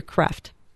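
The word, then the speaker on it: Kreeft: And lastly, I just started a book called How to Be Holy by Peter Kreft. – Mm-hmm. – Is that how you pronounce his yes. – name? – Yes.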